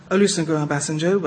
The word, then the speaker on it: Olusegun Obasanjo: Olusegun Obasanjo, well, obviously.